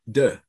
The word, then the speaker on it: Deux: So it's called Deux.